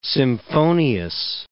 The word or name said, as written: symphonious